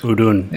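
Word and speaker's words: Udûn: How do you pronounce this word? Udun. So, Is it Udun? – Or? I think you, I think you got it. All right, Udun.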